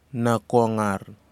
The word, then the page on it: nakgar: kwamera